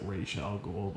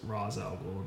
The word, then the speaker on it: Ra's al Ghul: Especially the scene where my favorite part of that movie is the scene where he's at the mansion and technically you're supposed to pronounce it Ra's al Ghul, but it's Ra's al Ghul in the movie.